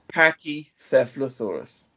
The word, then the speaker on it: Pachycephalosaurus: Pachycephalosaurus. – Oh, thank you very much. There we go. – Commonly, I knew there was a reason I kept you on the show. – Commonly abbreviated to Pachycephalosaurus.